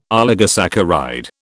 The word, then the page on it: oligosaccharide: British English